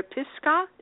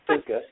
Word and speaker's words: Pisgah: Southern California, watch out east of Los Angeles near Pisgah. I'm not saying that right, am I? Pisgah. – It's Pisgah.